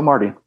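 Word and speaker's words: Omartian: How do you pronounce it? Omardian? – Okay. Michael Omardian. Yeah.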